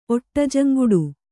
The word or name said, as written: ಒಟ್ಟಜಂಗುಡು